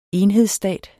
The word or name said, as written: enhedsstat